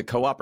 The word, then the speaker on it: Coop: Coop encompasses? Am I saying that right? Is it co-op or coop? – Formerly Index Cooperative. The coop for short is a fun little shorthand.